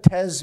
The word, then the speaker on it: Tes: Alexa, T-E-S. How do I say that, Tez? – T-E-S? – Yeah, Alexa Tez Bell.